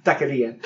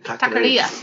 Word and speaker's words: taqueria: Taqueria. – Taqueria.